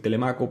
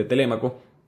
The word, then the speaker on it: Telémaco: Y tenemos a, Penélope, su esposa, y a su hijo, Telémaco, que yo de vez en cuando lo voy a decir Telemaco porque así lo aprendí, en Maracaibo se pronuncia así, pero se pronuncia correctamente Telémaco.